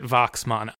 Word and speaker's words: Waxman: I am going to pronounce it Waxman. I get the impression from the Oscar ceremony that in 1950 he was being called Waxman by his peers.